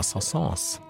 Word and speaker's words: Saint-Saëns: Hatte auch er deinem Spezialfreund Camille Saint-Saëns Ah, Sansans. Hat der Horror auch viel Spaß gemacht. Äh, nicht nur die Aussprache seines Namens ist für manche ein Graus, nein, er mhm.